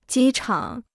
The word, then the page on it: 机场: jī chǎng